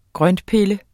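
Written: GRØNTPILLE

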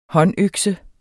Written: håndøkse

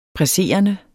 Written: presserende

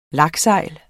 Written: laksegl

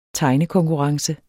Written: tegnekonkurrence

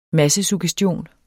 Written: massesuggestion

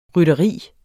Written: rytteri

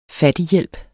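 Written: FATTIGHJÆLPEN

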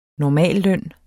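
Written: NORMALLØNNEN

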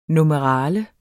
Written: NUMERALIER

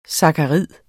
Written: sakkarid

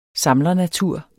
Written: samlernatur

